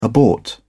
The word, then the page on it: abort: British English